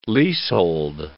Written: leasehold

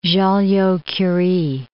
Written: Joliot-Curie

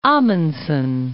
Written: Amundsen Gulf

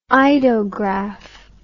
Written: eidograph

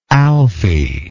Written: alfie

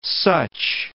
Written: such

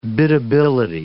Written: biddability

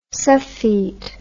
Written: suffete

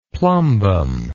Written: plumbum

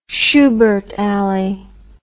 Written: Shubert Alley

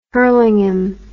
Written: Hurlingham

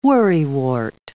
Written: worrywart

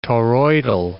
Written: toroidal

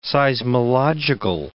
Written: seismological

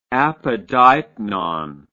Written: apodeipnon

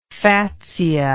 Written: fatsia

